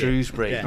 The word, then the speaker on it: Shrewsbury: That's how you say it. It's Shrewsbury, not Shrewsbury. Shrewsbury. It's Shrewsbury.